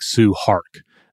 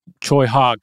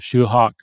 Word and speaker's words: Tsui Hark: and it looks kind of like Sue Hark. That might be the way you're kind of like, you mentally pronounce it. But it's more like Choi Hawk, I've heard, sort of a Shoe Hawk, but Hawk will be the way that I'm going to predominantly be referring to him.